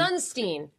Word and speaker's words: Sunstein: Sunstein.